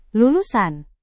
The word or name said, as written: lulusan